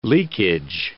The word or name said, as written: leakage